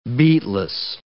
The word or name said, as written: beatless